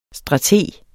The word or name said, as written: strateg